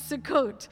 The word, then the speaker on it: Sukkot: It's the autumn harvest, and this one I can actually pronounce, Sukkot. That's an easy one. I said, okay, Sue, and Lindsay was like, Sue and coat, Mom. You can't get that wrong. Sue and coat. It's Sukkot.